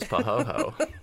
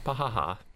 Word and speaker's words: pahoehoe: Not pahaha. – Okay, pahoho. It seems too easy. – Pahoho. – P-A-H-O-H-O. – Sorry if I had my bell. P-A-H-O-E-H-O-E.